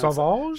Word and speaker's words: Sauvage: How do you pronounce Sauvage? – It? Sauvage. It's very French.